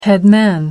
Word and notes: headman: American English

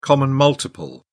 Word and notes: common multiple: British English

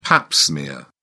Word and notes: pap smear: British English

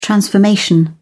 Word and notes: transformation: British English

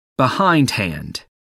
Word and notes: behindhand: American English

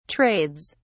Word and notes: trades: British English

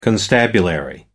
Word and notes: constabulary: American English

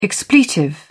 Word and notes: expletive: British English

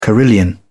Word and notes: carillon: British English

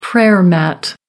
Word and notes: prayer mat: American English

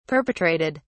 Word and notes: perpetrated: British English

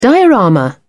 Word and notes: diorama: British English

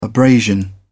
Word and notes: abrasion: British English

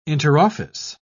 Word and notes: interoffice: British English

